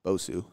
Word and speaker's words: Bosu: Next one is a, I can't pronounce this, Bosu ball? – Bosu. – Bosu ball and unstable stability training in general.